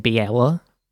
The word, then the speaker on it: Baela: Last episode, I kept on saying Baela, but it's Baela. I don't know why I kept on saying that. I wanted to hit every syllable.